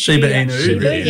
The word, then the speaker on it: Shiba Inu: But then I kept hearing about its younger cousin, if you were Shibu Yu. Is that how you say it? – Shiba Inu. – Yeah, that's it.